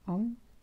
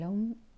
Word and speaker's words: Om: Om se pronuncia con tres letras: A, U, M.